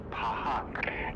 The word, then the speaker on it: Phuq: It's a classic, and the album that follows this, P-H-U-Q, or fuck, pronounced pahuk,